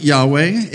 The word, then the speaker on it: Yahweh: In modern times, we pronounce it Yahweh.